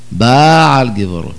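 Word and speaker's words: בעל גבורות: כשעומדים בתפילה אומרים חמוך בעל גיבורות עומדו מלך, אומרים בעל גיבורות, הם חושבים עשו מסווה גדולה, רחמנא ליצלן, בעל גיבורות זה לשון בעילה, איזה טיפשות, בעל גיבורות, מלעיל, לא בעל, בעל זה כמס פתח, בעל זה פתח פתח, לכן צריך מאוד לשים לב, המלעיל מלעיל, והמלרע מלרע, לא להפוך.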